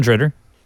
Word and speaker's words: DeRidder: That's how you say DeRidder. That's the correct pronunciation.